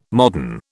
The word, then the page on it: modern: British English